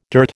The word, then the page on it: dirt: American English